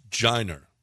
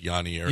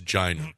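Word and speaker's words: Yainer: It's pronounced Jiner. Jiner. That's his name. I know it looks like Yonier, yeah, but it's Jiner. That's how you pronounce it.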